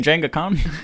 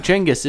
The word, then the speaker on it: Genghis: You mean Jenga Khan? – I think Genghis is the technical correct pronunciation.